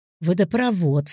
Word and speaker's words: водопровод: Вред, о вреде, водопровод, все слова с подобным корнем имеют ударение на вод.